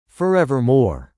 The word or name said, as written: forevermore